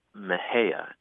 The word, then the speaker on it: Mexia: We have a funny town here called Mejia, and it's spelled M-E-X-I-A. – Mm. – And I think if you're an out-of-towner, a Yankee, you might be tempted to say Mexia, right? – Mm-hmm.